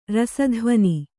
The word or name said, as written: ರಸಧ್ವನಿ